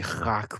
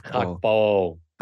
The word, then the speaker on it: Gakpo: Cody Gakpo. – Gakpo.